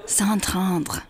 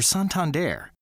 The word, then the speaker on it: Santander: It's okay to say Santander like your neighborhood librarian. – Santander. – Your daughter's boyfriend. – Whatever. – Your friend who just got back from Paris. – It's pronounced Santandre. – Or the guy who runs that old-timey riverboat cruise. – Santander! – The bottom line is we're Santander.